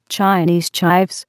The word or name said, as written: Chinese chives